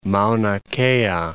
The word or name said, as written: Mauna Kea